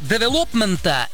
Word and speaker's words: девелопмента: С февраля этого года наблюдается падение спроса у девелопмента или девелопмента.